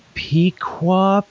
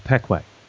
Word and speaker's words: Pequea: One that comes to mind as a local town is spelled P-E-Q-U-E-A. And you write that out and it's like, how would you say this? Pequa, Pequo? And for everybody who lives here, we just know it's Pequay.